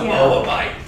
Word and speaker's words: Moabite: She's a Moabite, which is like, you say it like that. She's a Moabite.